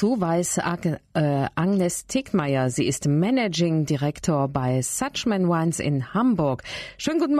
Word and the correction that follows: Schuchman: Jetzt habe ich den Namen falsch ausgesprochen. Schuchman Wines heißt ja, es ganz genau. – Ja, richtig.